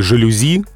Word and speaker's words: жалюзи: Да, французские заимствования почти всегда сохраняют ударение на последнем слоге. Те же самые «жалюзи» именно с таким ударением произносятся, потому что заимствование с французского. И как бы не хотелось сказать с ударением на первом слоге, но нам этого словаря не разрешают.